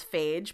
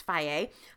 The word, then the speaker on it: Fage: Faye, which some of you might pronounce phage, but I do believe that it's Faye, F-A-G-E,